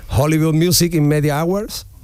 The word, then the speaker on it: Hollywood Music in Media Awards: Hollywood Music in Media Awards, y Hollywood Independent Music Awards. ¿Lo he dicho bien, más o menos? – Sí, ¿no? Sí, no, no, perfectamente.